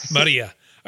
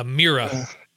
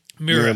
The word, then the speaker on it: Mirai: It's spelled M-I-R-A-I. So I'm going with Maria. All right. – Maria. – Sorry. Or Mira. Mira. We'll go Mira. With Mira. Mira sounds better. – I like Mira.